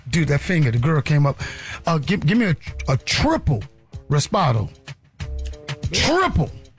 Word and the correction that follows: Reposado: Reposado, yeah. – I said, Reposado. That's how you pronounce it.